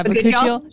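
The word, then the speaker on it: bakuchiol: I did see that your retinol also has, is it, how do you pronounce it? – Bacuchiol? – Yeah, Bacuchiol.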